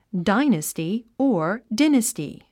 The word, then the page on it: dynasty: dáinəsti; ダイナスティ